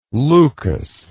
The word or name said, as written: leucas